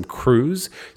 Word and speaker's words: Cruise: It's the last name Cruz, C-R-U-I-S-E.